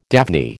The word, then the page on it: daphne: American English